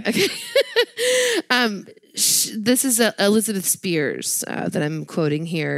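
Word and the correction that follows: Spiers: Excuse me. It's pronounced Spires. – Spires. – I've actually said that in her Twitter bio. – S-P-E-I-E-R-S, right? – She's a writer.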